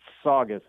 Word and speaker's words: Saugus: Saugus? How do we say that? In Boston. – You would say it Saugus.